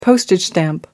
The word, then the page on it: postage stamp: American English